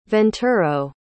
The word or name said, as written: venturo